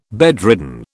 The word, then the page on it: bedridden: British English